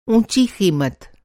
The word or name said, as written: ऊँचा